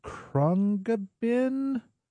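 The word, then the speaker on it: Khruangbin: That is some Krungbin. Rob Beckett, am I pronouncing that correctly? – I believe you are.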